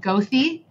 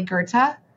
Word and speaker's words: Goethe: Like Goethe, like the street in Chicago that's spelled Gothy, but it's really Goethe.